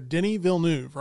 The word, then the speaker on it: Denis Villeneuve: Denis Villeneuve, right? – Yeah.